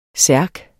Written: særk

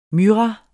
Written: MYRRAEN

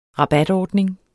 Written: rabatordning

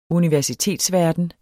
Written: universitetsverden

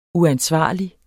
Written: uansvarlig

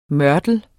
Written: mørtel

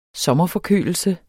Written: sommerforkølelse

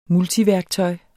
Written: multiværktøj

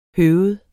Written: høved

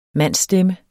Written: mandsstemme

